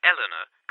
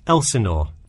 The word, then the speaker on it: Elsinore: Was that Eleanor? Could you spell that for me? – Sure. It's Elsinore. E-L-S-I-N-O-R-E.